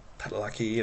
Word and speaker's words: Padalecki: Padalecki.